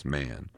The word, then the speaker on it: Mahon: He had a Roman nose, a snow white, long beard, and his name was Mahon but he said he insisted that it should be pronounced man.